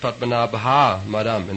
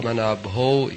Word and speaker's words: Padmanabho: He pronounced it Padmanabha Madam instead of Bho. – Он произнес слово Бха вместо слова Бхо. – Padmanabho, he pronounced it Padmanabha Madam.